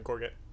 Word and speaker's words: gorget: Go ahead and give me a persuasion check with advantage, Grimton, while you're wearing the gorget.